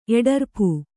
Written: ಎಡರ್ಪು

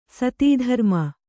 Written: ಸತೀಧರ್ಮ

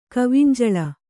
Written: ಕವಿಂಜಳ

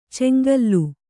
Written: ಚೆಂಗೂಲಿ

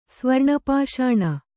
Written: ಸ್ವರ್ಣಪಾಷಾಣ

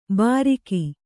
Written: ಬಾರೀಕಿ

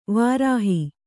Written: ವಿರಹ

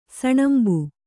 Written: ಸಣಂಬು

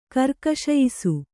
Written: ಕರ್ಕಶಯಿಸು

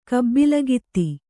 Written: ಕಬ್ಬಲಗಿತ್ತಿ

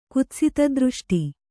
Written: ಕುತ್ಸಿತದೃಷ್ಟಿ

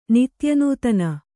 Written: ನಿತ್ಯನೂತನ

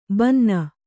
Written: ಬನೀನು

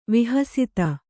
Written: ವಿಹಸ್ತ

